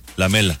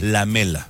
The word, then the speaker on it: Lamela: Lamela.